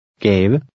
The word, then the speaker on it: gave: In number 3, the V and E are pronounced. Try to read number 3. – Gave.